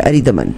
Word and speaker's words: अरिदमन: मगर डॉक्टर साहब ने नाम गलत तो नहीं प्रोनाउंस किया, अरिदमन, बिल्कुल सही है जी,